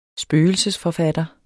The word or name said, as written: spøgelsesforfatter